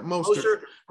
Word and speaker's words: Mostert: Mostert.